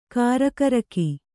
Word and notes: ಗುರಿಗಾರಿಕೆ: Kannada

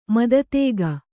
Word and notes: ಮಾತುತೆಗೆ: Kannada; mātu tege

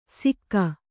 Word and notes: ಸಿಕ್ಕಾ: sikkā

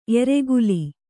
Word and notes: ಏರುಗೋಲ್: ērugōlu

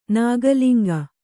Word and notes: ನಾಗಲಿಂಗ: Kannada; nāga liŋga

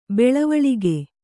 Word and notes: ಬೆಳವಳಿಗೆ: kannada

